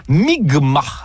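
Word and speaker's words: Mi'kmaq: nation Micmac, qui se prononce en fait Mi'kma, dans ce qui sera le premier contact.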